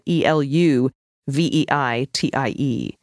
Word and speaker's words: Eluveitie: It's spelled E L U V E I T I E, Eluveitie.